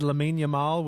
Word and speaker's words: Lamine Yamal: If I said Lamine Yamal, would that sound right to you? – Yeah, that sounds right.